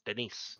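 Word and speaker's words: Denis: It's not even Dennis. It's D-E-N-I-S. Denise. – Denise. – Could be Denise.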